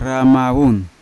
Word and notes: ramavun: Yawa

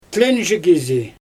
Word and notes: tlenʒi kizi: Xyzyl

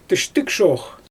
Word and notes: tɯʃtɯgʃox: Xyzyl

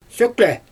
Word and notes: søkle: Xyzyl

